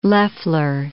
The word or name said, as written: Loffler